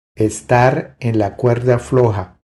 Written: Estar en la cuerda floja

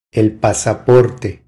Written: El pasaporte

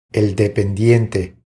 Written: El dependiente

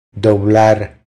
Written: Doblar

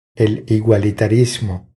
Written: El igualitarismo

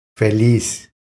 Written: Feliz

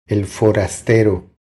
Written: El forastero

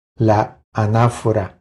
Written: La anafora

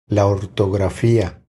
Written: La ortografia

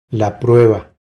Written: La prueba

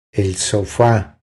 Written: El sofa

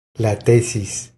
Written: La tesis

